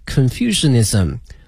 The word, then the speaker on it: Confucianism: If you are interested in Buddhism, Taoism, and Confucianism, 三个教哈一个是佛教道教和儒教哈那么第一个呢叫佛教英文的拼写呢是 B-U-D-D-H-I-S-M 它的发音是 Buddhism 道教呢是 T-A-O-I-S-M T-A-O-I-S-M 是 Taoism 儒教哈叫 Confucianism 它的拼写是 C-O-N-F-U-C-I-A-N-I-S-M